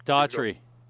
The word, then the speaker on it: Daughtry: Is that how you pronounce it? Like the American Idol winner? – Yes, Daughtry. – Daughtry.